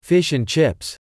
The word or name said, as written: fish and chips